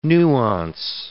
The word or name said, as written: nuance